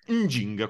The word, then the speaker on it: Njinga: E stessa roba, e qua c'è questa um, regina angolana del XVII secolo che si chiama Njinga. Nginga. Non so, non so pronunciarlo. – Nginga, come l'andrangheta.